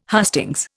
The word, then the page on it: hustings: Indian